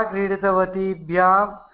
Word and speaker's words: क्रीडितवतीभ्यां: एकनिमिषः महोदय, महोदय अत्र अत्र दीर्घ ईकारः उच्चारणम् सम्यक् भवतु. भवत्या भवतीभ्यां भवतीभिः इति अस्ति किल, तादृशं क्रीडितवत्या क्रीडितवतीभ्यां क्रीडितवतीभिः इति दीर्घ ईकारः स्पष्टतया उच्चारणम् आवश्यकमस्ति. – क्रीडितवत्या क्रीडितवतीभ्यां क्रीडितवतीः क्रीडितवतीभ्यः.